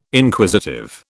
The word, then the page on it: Inquisitive: British English